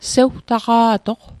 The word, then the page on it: sewtaqa'toq: Mi'gmaq